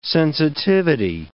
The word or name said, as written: sensitivity